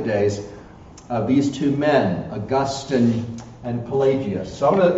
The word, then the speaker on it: Augustine: Let me just stop and say right now, it's pronounced Augustine.